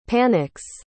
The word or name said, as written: panic's